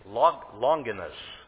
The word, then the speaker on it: Longinus: They gave me his name, Longinus, if I'm pronouncing it right. L-O-N-G-I-N-U-S, Longinus.